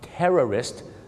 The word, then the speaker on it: terrorist: terrorist 2 คำนี้มีเส้นบางๆอยู่นะครับในการออกเสียงคือพยางค์2 พยางค์ tourist นักท่องเที่ยวกับ terrorist 3 พยางค์ครับผมน่ะกลัวนะครับว่าคุณจะเป็นเหมือนลูกศิษย์ผมคนนึงครับฝรั่งถามว่า Why did you come to America?